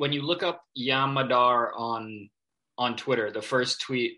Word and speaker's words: Yam Madar: I think he's 6'3", 6'4", but it's – I believe his name is Yam Madar. Is that how you say it? – Yam Madar, yeah.